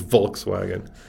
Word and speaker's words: Volkswagen: So In German, a W is a V, right? So it is Volkswagen.